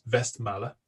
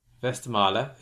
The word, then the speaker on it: Westmalle: So I presume it's pronounced Westmalle. – Westmalle.